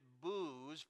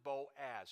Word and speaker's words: Boaz: it's the Greek spelling, B-O-O-Z. Don't pronounce it booze, but let's pronounce it Boaz.